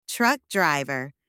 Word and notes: truck driver: anglais

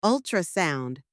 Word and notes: ultrasound: anglais

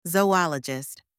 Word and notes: zoologist: anglais

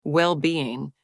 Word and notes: well-being: anglais

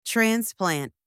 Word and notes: transplant: anglais

